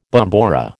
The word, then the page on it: bombora: American English